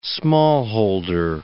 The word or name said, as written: smallholder